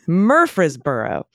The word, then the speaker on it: Murfreesboro: Murfreesboro. The gratuitous E might make you want to say free in the middle of a city name, but that syllable is usually pronounced fre, as in Fresno, Murfreesboro.